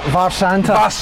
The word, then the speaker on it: Mharsanta: Mharsanta, spelt with an MH, just in case you didn't know.